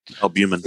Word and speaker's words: albumin: It's supposedly it cuts down, you know, on the alubulum or whatever what is that? – Albumin. – It's approaching. Albumin. I can't pronounce it. Albumin. There we go.